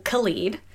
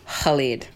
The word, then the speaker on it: Khalid: So Khalid is a name that I grew up pronouncing Khalid. – Yes, because there are people named Khalid. – Yes. – But in the Arab world, that K sound doesn't make the same sound. So yeah. It's Khalid.